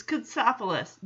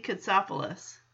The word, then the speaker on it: Katsopoulos: And Jesse corrects him, says, it's Katsopoulos, Jesse Katsopoulos.